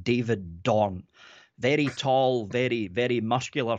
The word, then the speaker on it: Dorn: Is it D-O-N-G? Like a dick? – Dorn. – D-O-R-N. Oh, Dawn. Dawn. It's the Miss Doubtfire accent.